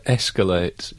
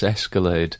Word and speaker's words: escalate: My brother phoned me yesterday to say that his girlfriend mispronounces a word, but it's the word escalate. She says escalade,